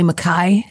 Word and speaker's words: Mackay: Allie Mackay is how you're supposed to pronounce my last name, but then you sound like a pirate here in America.